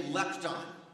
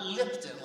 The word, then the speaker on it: lepton: Everybody say lepton. Lepton, not Lipton like the tea.